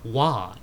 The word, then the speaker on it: Roy: And that's Patrick Wah is the goalie. He's French-Canadian goalie. Wah? Wah, R-O-I. But in French-Canadian, it's Wah. I'm like, dude, your name is Roy, but it's pronounced Wah.